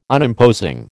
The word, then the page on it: Unimposing: American English